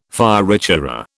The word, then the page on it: fioritura: British English